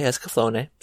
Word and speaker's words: Escaflowne: We are watching the Vision of Escaflowne, which I always thought was Escaflowne, but they do say Escaflowne, so that is how it's said.